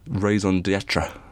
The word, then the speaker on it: raison d'être: As soon as, like, the whole sort of raison d'être, which is exactly how you pronounce that, in Game of Thrones, is that no one is safe.